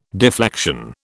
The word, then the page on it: deflection: British English